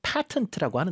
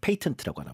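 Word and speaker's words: patent: 미국 발음은 또 patent라고 하는데. – 네. – 근데 이제 영어 발음은 patent라고 하나 봐요.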